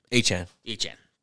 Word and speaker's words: Achane: Achane.